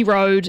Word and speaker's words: Rode: Rocky Road is how you say her name. It's spelt Road like R-O-D-E.